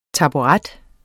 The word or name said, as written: taburet